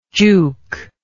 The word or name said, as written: jouk